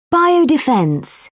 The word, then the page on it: biodefence: British English